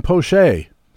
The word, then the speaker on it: Poche: This week's frequent flyer is Tampa reliever Colin Poche.